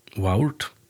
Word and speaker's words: waurd: Och stort lite är att agotiskon verda Waurd, W-A-U-R-D, men A-U uttala som Å, så her verda Waurd.